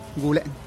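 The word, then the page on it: gu le': Teotitlán del Valle Zapotec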